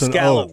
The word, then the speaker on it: scallop: It's an scallop. O. It's spelled with an A, but it's pronounced with an O.